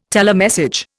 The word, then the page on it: telemessage: Indian